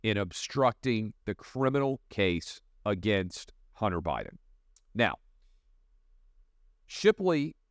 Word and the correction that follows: Shapley: has, in my mind, let me make sure I'm getting his name right, by the way, Gary Shapley has no, this is an IRS agent, Gary Shapley has no incentive to lie.